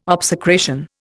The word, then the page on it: obsecration: Indian